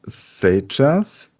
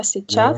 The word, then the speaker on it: сейчас: Ja, hier schreibt man zum Beispiel сейчас, ja, сейчас. Mit diesem i, beziehungsweise diesem umgedrehten n und obendrauf dieses umgedrehte Dach, Ja. – ja, сейчас, aber man spricht es nicht aus. – Was spricht man nicht aus? – Dieses i.